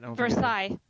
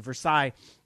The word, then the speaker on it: Versailles: So there was an office in Versailles. Did I say that right? – Versailles. – Thank you. Versailles.